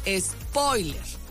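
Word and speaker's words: espóiler: le puso acento y todo, lleva acento en la O, spoiler, ¿no?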